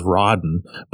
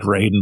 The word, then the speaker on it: Raiden: The way you just pronounced that, Raiden, I was in my head, I was Rodden. But I think it is like Raiden because it's like Mortal Kombat with the lightning because she is bringing down the thunder on everyone she comes across.